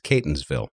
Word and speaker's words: Catonsville: Just so you know, Catonsville is pronounced with a long A. It's Catonsville.